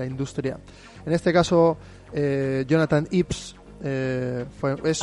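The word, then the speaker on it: Jonathan Ive: pues bien pronunciado, es algo así como Jonathan Ives.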